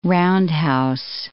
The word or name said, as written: roundhouse